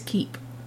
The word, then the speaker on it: Koepp: I think it's Keep. – Is it? – Yeah. – K-O-E-P-P. Koepp? – I think it's Keep. – Keep. – I think K-E-E-P. – So. No. You say it like that.